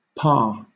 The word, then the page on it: pa: British English